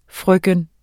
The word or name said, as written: frøken